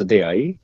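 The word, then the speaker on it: Deai: Название произносится D.I. – D.I.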